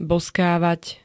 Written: bozkávať

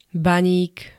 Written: baník

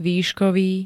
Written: výškový

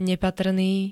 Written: nepatrný

